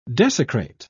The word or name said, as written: desecrate